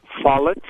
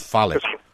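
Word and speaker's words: Follet's: Follets, F-O-L-L. E-T apostrophe S, I believe. – Oh, Follett.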